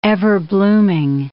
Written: everblooming

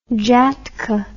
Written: jhatka

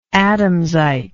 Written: adamsite

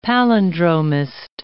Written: palindromist

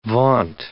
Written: vaunt

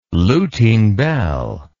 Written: Lutine bell

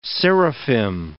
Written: seraphim

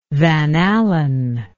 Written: van allen

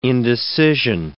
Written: indecision

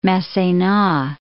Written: Massena

